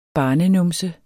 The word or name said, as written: barnenumse